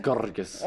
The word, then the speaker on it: Grgas: How do you say it? – Yeah. It's Grgas.